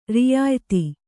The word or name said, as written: ರಿಯಾಯ್ತಿ